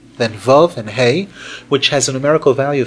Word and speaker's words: Hey: Note the way we pronounce that letter, hey. Hey, it's as if there's a yud at the end.